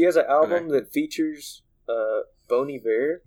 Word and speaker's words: Bon Iver: Yeah, you say it Bon Iver, it's like a French thing.